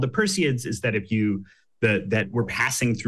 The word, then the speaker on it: Perseids: You're going to have to pronounce it for me. It's from Perseus, right? – Yeah, Perseids is what they're Perseids. – Called. – The Perseids.